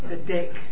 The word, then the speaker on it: Didache: Didache? Didache? – Thank you. – It's the Dake. It's just two syllables.